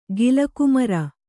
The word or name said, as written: ಗಿಲಕುಮರ